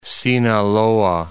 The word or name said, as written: Sinaloa